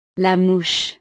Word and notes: la mouche: frances

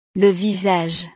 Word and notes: le visage: frances

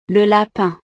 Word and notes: le lapin: frances

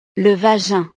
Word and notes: le vagin: frances

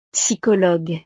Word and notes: psychologue: frances

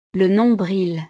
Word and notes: le nombril: frances

